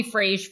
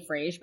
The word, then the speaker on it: Frage: People used to call me Frage for years, which is why I named the show Hey Frage. But the irony is no one can pronounce it. So most people are like, Hey Frase. Hey Frase.